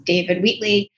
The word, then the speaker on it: David Wheatley: I am, as with every episode, super excited to have my guest, David Wheatley.